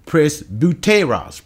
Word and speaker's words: presbyteros: Now, the word here used for elders is presbyteros, that's the right pronunciation.